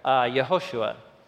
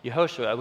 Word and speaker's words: Yehoshua: So the meaning of his name in Hebrew, actually how you would have pronounced his full name in Hebrew, the traditional form is up top there, Yehoshua. Yehoshua, which is where we get the name Joshua in English as well.